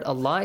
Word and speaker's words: Allah: So, and the letter lam in the word Allah is pronounced how? Heavily or lightly? – Heavily. – Heavily. Why? – Because there is zamma before.